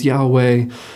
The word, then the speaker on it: Yahweh: the two syllables Yahweh, sound almost onomatopoeic for breath. That inhale of Yah and the exhale of Hoy.